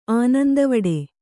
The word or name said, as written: ಆನಂದವಡೆ